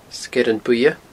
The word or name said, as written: Sgeirean Buidhe